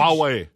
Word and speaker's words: Huawei: That great big huge Huawei.